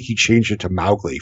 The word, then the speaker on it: Mowgli: His daughter, like, said that she never, like, the Kipling estate never forgave Disney because Mowgli is supposed to be pronounced Mowgli, and, like, completely changed the pronunciation forever.